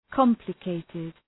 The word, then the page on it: complicated: ‘kɒmplə,keıtıd